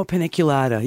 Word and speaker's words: paniculata: The name of it, I'm going to probably pronounce it wrong. Panicotula? – Oh, paniculata. Yeah, no, that's fine.